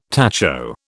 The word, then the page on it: tacho: British English